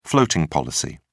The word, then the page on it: floating policy: British English